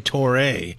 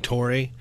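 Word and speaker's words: Touré: Well, we'll hear from one of Nebraska's newcomers, wide receiver Samare Torre. He was asked how to pronounce his last name at yesterday's presser. Most of the media have been saying Torre. That's Torre. – Oh, Torre.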